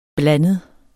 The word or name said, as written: blandet